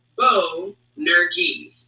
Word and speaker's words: Boanerges: let's see, it's pronounced Boanerges. I'm going to get this. It's Boanerges. Okay, so Boanerges, and I actually heard various translations of this.